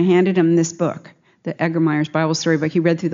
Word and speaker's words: Egermeier's: It is pronounced, it's spelled E-G-E-R-M-E-I-E-R-S, Egermeyer's.